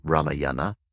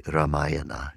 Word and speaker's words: Ramayana: Ramayana is often pronounced in the West as Ramayana. That's a mispronunciation. But we say Ramayana in the Vedic language.